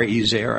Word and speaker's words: ezer: The Hebrew word is phonetically E-Z-E-R, Ezer, I suppose it's pronounced Ezer.